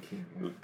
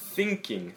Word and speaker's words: thinking: 그래서 그 다른 의미 가지고 있으니까 S나 뭐 시옷, 상시옷 그 발음으로 하지 마시고요. 정말 thinking, thinking 이라고 하셔야 돼요.